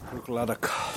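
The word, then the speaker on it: Bruichladdich: So there's a scotch called Brooked Laddie that I really like. – No, where's that from? – Brooked Laddock. – Thank you. I can't pronounce it right.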